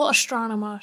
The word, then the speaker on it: Astronema: Like a little Astronema shrine. – There you go. – No. – Yeah, that was okay. Right. You said it right.